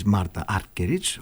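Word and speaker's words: Martha Argerich: ήταν από ζωντανή ηχογράφηση στο φεστιβάλ της Μάρθας Άργκεριτς, της Μάρτα Άργκεριτς, όπως σωστά πρέπει να προφέρεται,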